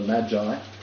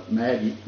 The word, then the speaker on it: Magi: Now, that's how you pronounce it. Some call it the Magi. Have you ever heard someone call it, you know, the Maggie? Well, no, not the Maggie.